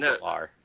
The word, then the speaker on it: Gellar: Is it Galar? – It is actually Galar. – I don't know what its proper pronunciation is. – She actually did clear it up like years later. It is Galar.